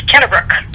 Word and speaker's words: Kennebrook: No, it's Kennebrook.